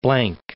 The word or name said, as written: Blanc, Cape